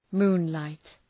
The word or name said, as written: moonlight